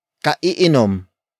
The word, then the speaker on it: kaiinom: Another example, kaiinom lamang niya ng kape. Kaiinom lamang niya ng kape. Kaiinom lamang niya ng kape. It means he or she just drank coffee. Word for word, it's kaiinom lamang niya ng kape.